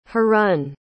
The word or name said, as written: harun